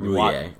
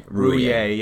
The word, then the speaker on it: Roullier: Chase, uh, I don't even know how to say his last name. – Rouye. Rouye, yeah.